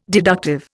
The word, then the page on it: deductive: Indian